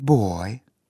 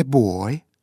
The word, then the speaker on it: boy: Оно звучит у тебя сильно, напористо, как русское слово «стой». А произносить его надо так, как будто в конце слова убавили звук. A boy, a boy.